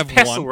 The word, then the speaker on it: pestle: It's pestle, right? You don't pronounce the T, right? – I think it's pestle.